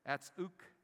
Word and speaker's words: anapsyxis: It's the Greek word apsoukis. It's the transliteration that's spelled A-N-A-P-S-Y-X-I-S.